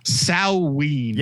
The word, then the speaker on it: Samhain: Apparently is pronounced Samhain. – Yep, it's correct.